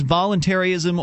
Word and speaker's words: voluntarism: But I want to give you a real simple scenario to see whether or not I have a basic understanding of volunteerism. – It's usually pronounced voluntarism or voluntarism. – Okay. – One or the other.